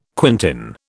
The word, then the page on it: quintain: British English